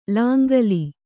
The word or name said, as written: ಲಾಂಗೂಲ